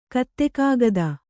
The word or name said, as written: ಗುತ್ತಕಾಗದ